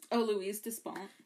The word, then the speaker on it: Louise Despont: Louise Despont.